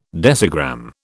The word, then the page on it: decigram: British English